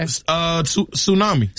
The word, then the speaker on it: tsunami: This has a silent letter in it. – Okay. – Tsunami.